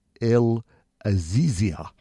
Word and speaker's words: El Azizia: Or anyway, the hottest spot on the planet is in a place called El Azizia. And it's spelt? – ELAZIZIA. Azizia.